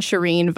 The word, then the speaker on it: Shirin: It's my name, Shireen Ghaffari, which is not easy to spell, so. – Well, spell it for us then. – Well, just Google S-H-I-R-I-N, Shireen Vox, and you will find me.